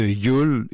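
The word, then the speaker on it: Jul: Silex and the City, avec le trait, le dessin grossier et hilarant de Yul. Yul, en passant, ça s'écrit J comme Jojo, U comme Uranus et L comme Lépidoptère.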